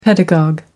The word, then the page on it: pedagogue: American English